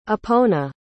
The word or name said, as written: epona